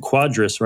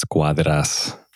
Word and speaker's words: Quadras: And I was like, it's Nelson Quadras, right? And then he's like, yeah, dude, that's like the fucking Chicago white boy way of saying it. How should it be pronounced? – If you want to get it, I guess, technically right, you're Hispanic or something, it's Cuadras. – Cuadras. Like, you gotta like put that much stank on it every time.